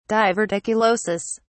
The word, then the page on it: diverticulosis: British English